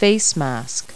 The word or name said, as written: facemask